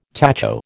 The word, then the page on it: tacho: American English